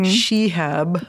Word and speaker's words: Shihab: Shihab mm-hmm.